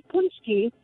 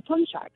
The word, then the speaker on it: Poonchki: It's said Poonchki, but when you say Poonchki, you're actually referring to more than one. That is the plural. An individual one is actually called a Poonchek.